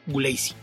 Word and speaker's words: Gulacy: Casos como el de Paul Gulacy, que se escribe Gulacy. Ahí también tenemos el problema de que mucha gente no sabe dónde es el apellido, pero la pronunciación tal y como lo hace es Gulacy.